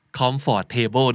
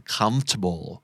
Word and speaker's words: comfortable: comfort table นั่นเองนะแต่ว่าเราอ่านว่า comfortable comfortable